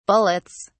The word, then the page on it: bullets: British English